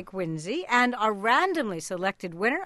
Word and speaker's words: Quincy: And Quincy, with a Z, is the proper pronunciation.